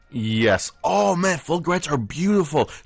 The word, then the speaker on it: fulgurites: They call it fulgurites. – Fulgurites. – Fulgurites. – How do you spell that? – That's F-U-L-G-U-R-I-T-E-S.